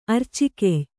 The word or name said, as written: ಅರ್ಚಿಕೆಯ್